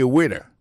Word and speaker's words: waiter: I am a waiter. Ça veut dire, moi, son garçon m'a servi dans le restaurant. Alors, moi, je prononce waiter. Waiter, ça veut dire accent tombé sous premier syllabe là. Waiter. W-A-I-T-E-R.